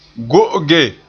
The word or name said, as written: go'oge